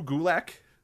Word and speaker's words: Gulak: Drew Gulak. – Okay. Rhymes with attack.